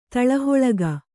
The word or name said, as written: ತಳಹೊಳಗ